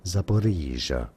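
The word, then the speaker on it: Zaporizhzhia: Zaporizhia.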